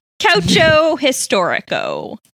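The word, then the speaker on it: Calcio Storico: How do you say that game? – Calcio nope. – Historico. – Nope. – Calcio. – Calcio. – Calcio. Calcio Historico. – Yep, that's exactly correct. That's 100% correct.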